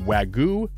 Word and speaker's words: Wagyu: By the way, I asked Max from Andrea's about the Wagyu debate, whether W-A-G-Y-U is pronounced Wagyu or Wagyu. He said Wagyu. – He did? – Yes. He said it's Wagyu. He said that's how I pronounce it. That's how we refer to it anyway.